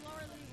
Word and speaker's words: Lauralee: I'm Laura Lee Rose. It's Laura, L-A-U-R-A-L-E-E. – See, I'm the weird one. I'm L-E-A-H.